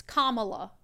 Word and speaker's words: Kamala: And she was like, I just want to let you know you're pronouncing Kamala Harris wrong. So apparently I say Kamala, mm-hmm. but it's Kamala.